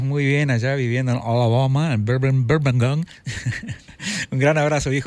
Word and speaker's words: Alabama: Hace rato se escuché, pusieron una de las canciones favoritas de Sweet Home Alabama, y tenés totalmente correcto, no se pronuncia Alabama, se dice Alabama.